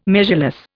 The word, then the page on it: measureless: Indian